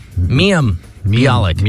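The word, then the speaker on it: Mayim Bialik: Is that how you say her name? – Miam Bialik. Miam Bialik.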